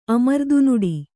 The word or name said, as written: ಅಮರ್ದುನುಡಿ